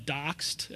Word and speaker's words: ZD0X3D: It's called Doxed. It's spelled ZD0X3D, lead speak.